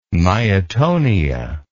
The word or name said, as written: myatonia